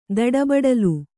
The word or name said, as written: ದಡಬಡಲು